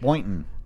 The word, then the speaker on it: Boynton: Boynton.